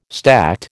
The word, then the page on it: stacte: American English